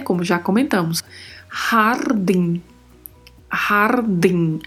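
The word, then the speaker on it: jardín: A pronúncia é din e com muita atenção, porque este N do final também não vai deixar a vogal ser muito aberta, né? Não pode falar de, é din, din, hardin.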